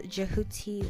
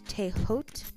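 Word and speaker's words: Djehuti: Now, Thoth, who can also be called Jehuti or pronounced Tehot, was known as the Master of the Masters, you guys.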